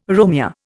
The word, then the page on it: Romeo: Indian